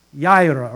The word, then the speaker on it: Yireh: And Genesis chapter 22, Genesis 22, verses 13 to 14, He is Jehovah Yireh, Y-I-R-E-H. Now, some pronunciations are Jehovah Jireh. It's actually Jehovah Yireh, or Jehovah Yireh. It's you don't pronounce J, but it's Yireh.